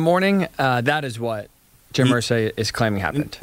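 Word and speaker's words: Irsay: Is it Ursay? How do you say this man's name? – Ursay. Jim Ursay.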